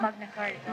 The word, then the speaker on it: Magna Carta: Magna Carta.